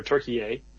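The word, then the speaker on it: Türkiye: a ton about Turkey or Turkey A, as the proper pronunciation.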